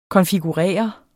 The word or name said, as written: konfigurere